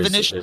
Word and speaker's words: Vinicius: Vinicius, that's how it's pronounced if anyone has any doubts. Um, not, Vinicius, not Vinicius. – Yes, yes, it's Vinicius, like the Real Madrid winger.